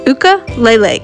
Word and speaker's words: ukulele: Ukulele. That's how they say it in Hawaii.